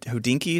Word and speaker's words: Hodinkee: Is it Hodinkee? Is that how you say it? – Yeah.